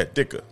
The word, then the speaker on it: Ditka: Yeah, Dicker. – You don't say the T? – Mike Dicker. Just listen.